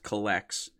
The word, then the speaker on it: Cullx: We also have a 10-year-old son and his dad have created an app to kind of help with pricing called, it's pronounced Collex, C-U-L-L-X.